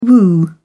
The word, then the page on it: woo: British English